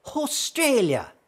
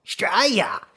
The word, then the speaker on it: Australia: There's the way Her Royal Highness Liz Regina would say it, like, Australia, as in, to make Australia less shit. For when you're, you know, dealing with matters of great national import. Or there's the more conversational, Straya, in which the first A is silent, like, as is, of course, the U.